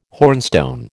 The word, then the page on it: hornstone: American English